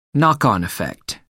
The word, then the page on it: knock-on effect: American English